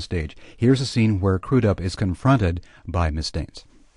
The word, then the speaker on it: Crudup: I'd always pronounced it Crudup until I met him, but it's pronounced Crudup.